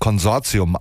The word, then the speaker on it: Consortium: So spricht sich nämlich Consortium aus. Ich habe mich jahrelang davor gedrückt und es immer deutsch ausgesprochen, damit es auch verständlicher ist.